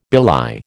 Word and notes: belie: American English

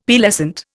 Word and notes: Pearlescent: Indian

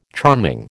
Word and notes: charming: American English

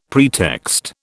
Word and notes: pretext: British English

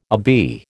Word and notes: obeah: American English

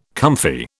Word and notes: comfy: British English

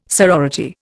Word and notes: sorority: Indian

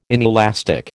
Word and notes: inelastic: American English

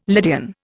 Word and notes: Lydian: Indian

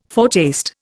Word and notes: foretaste: Indian

